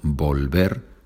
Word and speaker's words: volver: But as we know, the V is pronounced B, so volver, vuelto.